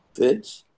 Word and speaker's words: Fitts: F-I-T-T-S. – Fitz.